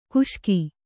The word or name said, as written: ಘೋಷಕ